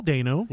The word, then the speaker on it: Dano: So I'm going to butcher this right now and say Royal Dano. – That's what I've always said. Yeah. – Yeah, right, just then. Like Paul Dano. – Yeah. – They're not related. But Royal Dano.